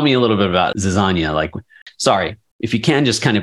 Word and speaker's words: Zizania: Mitch of Zizania Consulting. Is that also pronounced? – You were close. Zizania Consulting.